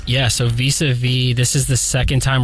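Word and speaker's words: vis-à-vis: Tell me if I pronounce this correctly. Vis-a-vis Volume 2? – Yeah.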